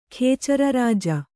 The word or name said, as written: ಖೇಚರರಾಜ